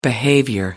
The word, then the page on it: behavior: British English